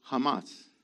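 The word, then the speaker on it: Hamas (חמס): It's chet mem samech. It's pronounced, the vowels for it is a short A and a short A. So it's Hamas. Hamas. Okay? The diacritical mark is on the M-A at the end. So it's Hamas. Not Hamas, but Hamas. And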